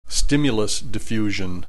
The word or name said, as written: stimulus diffusion